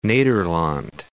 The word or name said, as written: Nederland